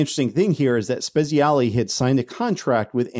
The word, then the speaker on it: Spezialy: Tom Spezialy, I believe is how he pronounced his last name.